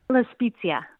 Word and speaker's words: La Spezia: La Spezia.